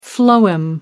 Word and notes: phloem: American English

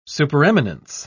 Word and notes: supereminence: British English

